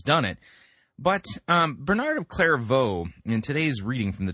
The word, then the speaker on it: Clairvaux: Yep, Clairvaux. Not clairvoyant, but Clairvaux.